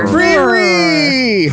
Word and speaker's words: Frere: You tell me how to pronounce F-R-E-R-E. – Yeah, that sounds about right. – Freery.